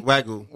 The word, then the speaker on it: Wagyu: Wagyu.